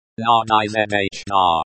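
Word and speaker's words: надежда: Pronounced nadezhda.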